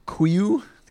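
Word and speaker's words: Kuiu: I was trying to pronounce that like an I. – Yeah, you're right. No, it definitely is an I. – Kuyu?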